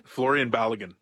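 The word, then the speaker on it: Folarin Balogun: Florian Balogun.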